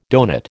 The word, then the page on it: donut: American English